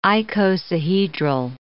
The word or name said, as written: icosahedral